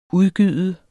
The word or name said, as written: udgyde